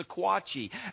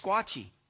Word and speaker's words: Sequatchie: We always said the Sequatchie. And I found out from Jesse it's the Squatchie.